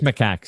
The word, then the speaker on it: macaque: And it is macaque. So you just pronounced it correctly.